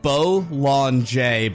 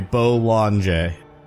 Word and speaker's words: Bollinger: Bollinger, but the J is very soft. Sorry, Bollinger.